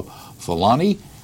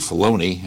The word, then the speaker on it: Filoni: Filoni. It's actually Filoni. I keep mispronouncing his name.